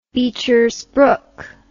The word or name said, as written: bechersbrook